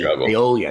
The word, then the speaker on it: aioli: The bacon avocado melt, applewood smoked bacon, avocado, smoked gouda, everything bagel seasoning, and chipotle aiello. How do you pronounce that? Aiello? Aiello? – I'm a little aioli. Aioli, I think it is. Aioli. – There you go.